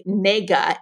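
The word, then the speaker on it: nega (那个): The professor mentioned that the Chinese use a word that is pronounced like nega, N-E-G-A, as a filler word.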